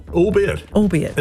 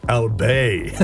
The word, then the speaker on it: albeit: Albeit. And thought it was pronounced French-style, albé. That's so good. I first said it correctly in my late 20s when Kim Hill said it.